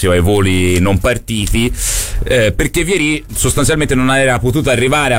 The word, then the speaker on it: Vieri: Allora, Benve, che tra poco andiamo a sentire, anzi direi a Tonellissimo di attivare il collegamento, dice: si dice Vieri, e per me Benve, che parla spesso con i brasiliani, c'ha ragione, però chissà.